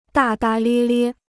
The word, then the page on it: 大大咧咧: dà dà liē liē